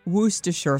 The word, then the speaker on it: Worcestershire: Some splashes of Worcestershire sauce. Said it right. – Yeah. – I think I said it right. Worcestershire sauce.